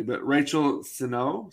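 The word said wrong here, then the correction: Sennott: Sinat, Sinet, Sinet, Sinet.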